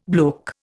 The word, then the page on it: bloke: Indian